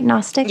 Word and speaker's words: Gnostic: Am I pronouncing that right, Gnostic? – Yes. – Okay, I just wanted to make sure. We got that silent G in there, so you never know.